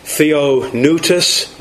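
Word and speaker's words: Theopneustos (θεόπνευστος): It is pronounced Theopneustos, which means literally God breathed.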